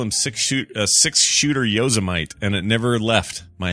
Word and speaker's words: Yosemite: Well, see, and I know it's Yosemite, but my friend's such a dingleberry.